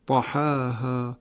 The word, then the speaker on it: طحاها: و همینطور آیه ششم همین سوره شمس طحاها که باز اینجا هم بدون اماله تلفظ میشه.